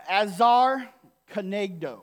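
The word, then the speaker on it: ezer kenegdo: But it's called, this word is pronounced uh, azar kanegdo.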